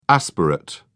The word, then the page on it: aspirate: British English